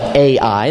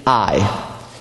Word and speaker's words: Ai: Then they faced off against this other city called, we all call it Ai. And that's okay if you call it that. But the proper pronunciation is simply Ai.